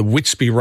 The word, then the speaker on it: Witsby: And look, looking after your family's health, it's got easier with the Witsby range. And I've told you about this. It's WITSBB. That's how it's spelt. It's Witsby.